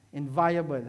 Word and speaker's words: inviolable: A parallel definition of this would be, right, the inviolable, how do you pronounce it? Inviolable position of going forward by the grace of God to know Him better.